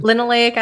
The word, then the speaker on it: linoleic: Linoleic acid. – Linoleic acid.